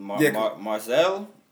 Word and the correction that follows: Marseille: Marseille, Marseille, Marseille, something.